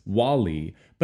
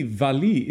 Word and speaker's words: Wally: The title of the opera looks like it should be pronounced Wally, but actually Valli is the nickname of the title character, Valburga, obviously.